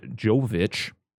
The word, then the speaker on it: Jovovich: And, you know, they got, the thing is like, Mila Jovovich, I looked up how to say her name.